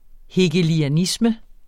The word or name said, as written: HEGELIANISME